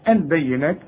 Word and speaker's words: البينات: مريم البينات، البينات كلها مرققة، وعندك فوق النون ألف علشان تقول البينات وتكتبها كده زي ما انت شايفها في المصحف، لكن تنطقها بالألف كاملة.